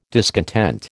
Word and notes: discontent: American English

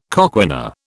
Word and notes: coquina: British English

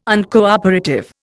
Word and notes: Uncooperative: Indian

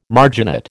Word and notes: marginate: American English

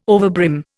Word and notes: overbrim: Indian

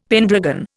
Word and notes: pendragon: Indian

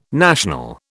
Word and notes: national: British English

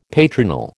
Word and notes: patronal: American English

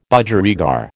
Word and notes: Budgerigar: American English

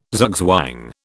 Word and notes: zugzwang: British English

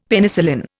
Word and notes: Penicillin: Indian

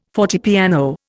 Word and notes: fortepiano: Indian

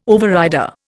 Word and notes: overrider: Indian

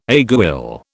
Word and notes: aiguille: British English

